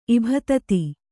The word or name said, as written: ಇಭತತಿ